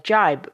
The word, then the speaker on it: gibe: To figure this one out, we really need to look at another word altogether, the word jibe, which I know sounds just like jibe, but this time it's spelled G-I-B-E.